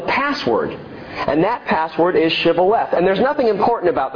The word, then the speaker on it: Shibboleth: And if he said no, they would say to him, say now, Shibboleth. Say this word, Shibboleth. But an Ephraimite, he said, Sibboleth. for he could not pronounce it correctly.